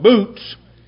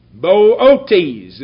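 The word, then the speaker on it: Boötes: And I had an awful time trying to call this word because I wanted to call it boots. But that's not what it is. I never saw a grammatical construction that has two long O's. And so what you have there is Bootes. Bootes is the way I would say it.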